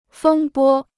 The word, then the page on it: 风波: fēng bō